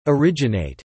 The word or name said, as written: Originate